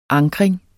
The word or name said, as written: ankring